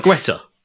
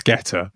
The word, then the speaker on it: Guetta: Is it not Guetta? – No, it's Guetta. – Oh, so the U's silent, is it? – Yeah.